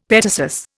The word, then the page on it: petasus: Indian